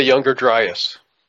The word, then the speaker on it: Younger Dryas: I think it's called the Young Dryas or something like that. It's got That's the Younger Dryas.